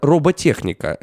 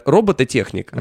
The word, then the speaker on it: роботехника: Это роботехника. Многие говорят робототехника. – Угу. Правильно говорить роботехника.